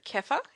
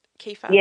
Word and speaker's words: kefir: And I may not say it correctly, but kefir, is it? Kefir. – Yeah, kefir. Yeah, it's a few different ways to say it.